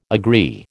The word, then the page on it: agree: American English